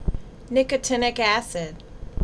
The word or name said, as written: Nicotinic Acid